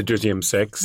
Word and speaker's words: deuxième sexe: How would you pronounce it in French? Your French is better than mine. – The deuxième sexe.